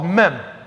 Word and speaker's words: Mem: It's called Mem. That's how they would pronounce it.